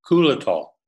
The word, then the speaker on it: Xylitol: Xylitol is spelled just like it is in U.S. in Finland, but it's pronounced Kulitol.